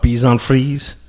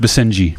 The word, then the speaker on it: Basenji: And these are what, Bison Frise? – Close. It's pronounced Basenji.